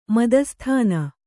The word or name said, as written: ಮಧುಸೂದನ